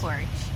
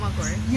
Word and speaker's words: McHorgh: McHorgh. – McHorgh. Is McHorgh your name? – McHorgh.